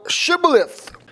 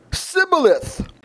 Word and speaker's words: Shibboleth: Then said they unto him, Say now, Shibboleth. And he said, Sibboleth. For he could not frame to pronounce it right.